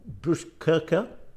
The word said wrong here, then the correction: Botkyrka: Botkyrka. – Botkyrka.